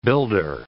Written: builder

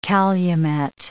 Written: calumet

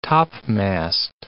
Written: topmast

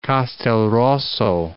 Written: Castelrosso

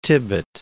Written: Tibbett

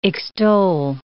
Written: extoll

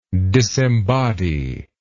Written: disembodiment

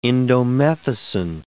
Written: indomethacin

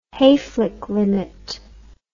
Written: hayflick limit

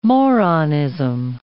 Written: moronism

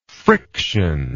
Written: frictionlessly

